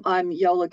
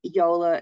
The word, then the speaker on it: Iola: I'm Iola Goulton. That's Iola is spelt with an I, not a Y, although it's pronounced with a Y,